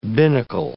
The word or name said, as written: binnacle